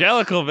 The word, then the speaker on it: Jellicle: Jellicle, baby. That's how you fucking pronounce that.